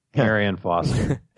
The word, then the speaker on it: Arian Foster: Uh, I never heard of Orion Foster. Is that how you pronounce it? Arian Foster.